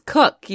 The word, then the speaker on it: Koch: K-O-C-H? Depends on who's saying it. – Okay. What? – K-O-C-H yeah. is cook, usually. – Okay. Whoa. Okay. Is it? – The one person I know with a last name spelled that way pronounces it cook.